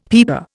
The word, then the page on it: peeper: Indian